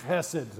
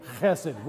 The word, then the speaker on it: chesed: But it's spelled H-E-S-E-D when you transliterate it into English. But it's that sound at the beginning. So we're all going to repeat after me. Chesed. So on three, chesed. One, two, three, chesed.